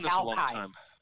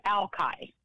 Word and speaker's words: Alki: No, it's pronounced Alki. – Yeah. – Oh, okay. It's Alki.